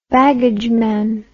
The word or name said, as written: baggageman